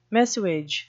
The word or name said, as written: messuage